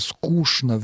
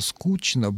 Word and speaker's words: скучно: Старомосковский вариант требовал изменения звука «ч» в звук «ш» в сочетаниях «ч-н», правда, не во всех словах, но во многих словах. И произношение «скучно» вместо «скучно», «булочное» вместо «булочное» — это сохранившиеся остатки старомосковского варианта литературного произношения. Именно сохранившиеся и именно остатки. Сегодня произношение «булочное» и «булочное» — это варианты. Можно и так, и так.